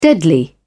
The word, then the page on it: deadly: British English